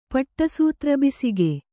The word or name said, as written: ಪಟ್ಟಸೂತ್ರಬಿಸಿಗೆ